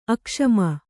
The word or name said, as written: ಅಕ್ಷಮೆ